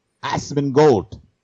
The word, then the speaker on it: Asmongold: But I mean, Assman Gold or how do you pronounce Asmongold? – It? – Assman Gold. Yes.